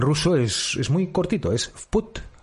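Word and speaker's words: Put (путь): Es una V, o el símbolo este que tienen ellos de suavizar un fonema, que es como escrito una V en minúscula, que se pronuncia V, y put, que es vamos, es put, así, es muy sencillo, es muy curioso.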